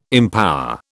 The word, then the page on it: empower: British English